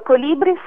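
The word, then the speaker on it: Kolibris: Sì, giusto, Colibris è scritto con la K perché ho scelto la parola tedesca perché l'accento cadeva nel punto giusto sui libri, Colibris, e quindi mi piaceva questa cosa, oltre all'idea ovviamente del colibrì, questo uccellino velocissimo e variopinto.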